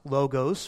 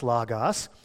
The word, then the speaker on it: logos: You may have heard it pronounced logos from time to time, but it's actually pronounced logos.